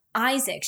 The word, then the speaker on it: Iisak: His name is Isaac. – Isaac. – I think is how you pronounce his name. – I think you pronounce it. It's like I-I-S-A-K. Isaac.